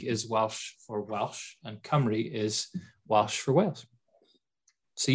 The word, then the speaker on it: Cymru: Chem. – No, there's more syllables than that. It's Cymru.